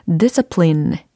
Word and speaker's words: discipline: discipline, discipline. discipline, discipline. 이렇게 하시면 되겠습니다. discipline이 아니고 discipline, discipline 이런 느낌이에요.